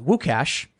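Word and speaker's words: Łukasz: By the way, I believe Luke is Lukasz in Polish, pronounced Wukasz.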